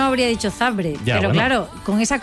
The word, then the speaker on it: Zabrze: sabes que es Zabrze? – ¿Por qué pronuncias Zabrze? – Porque es así.